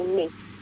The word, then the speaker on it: Nice: Well, it's spelled nice, but you say niece.